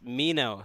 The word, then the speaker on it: Minot: What do you think about the theory or fact that it's pronounced Mino State? The T is silent.